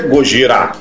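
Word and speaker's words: Gojira (ゴジラ): E como a gente comentou, em japonês é Gojira.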